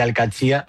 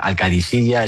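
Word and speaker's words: Al-Qadsiah: Bueno, se suele decir Al-Qadsia, pero también hay gente que dice Al-Qadisiyya. Es que es difícil, ¿eh? No es fácil, pero Al-Qadsia es lo más fácil.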